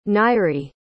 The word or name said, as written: nairy